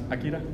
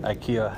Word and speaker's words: Akira: Akira and which way would be pronounced? Akira. – Akira, yeah.